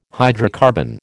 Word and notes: hydrocarbon: American English

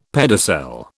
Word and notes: pedicel: British English

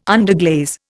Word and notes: underglaze: Indian

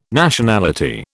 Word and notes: Nationality: British English

